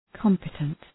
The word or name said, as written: competent